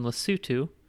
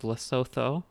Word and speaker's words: Lesotho: I was born in Lesotho, which most people think is pronounced Lesotho, but it's not.